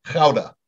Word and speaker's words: Gouda: The Dutch would pronounce it like K-H-O-W-D-A. Gouda. – Gouda. Uh-huh. And of course, and as an American, I always have called it Gouda, but you can see, boy, a thick rind on that and a whole different characteristic.